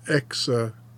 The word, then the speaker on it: exigencies: Exigencies. That's a hard one. E-X-I-G. Exit. There's no N in there, but you have to kind of throw one in to pronounce it. Exigencies.